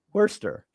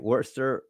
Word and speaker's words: Worcester: Coming in at number eight is, you know how to pronounce that? – Worcester. – That's right. Worcester, Massachusetts.